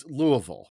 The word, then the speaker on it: Louisville: There's a major city that is pronounced Louisville, that's sort of slurred together, but it's named after the French monarch.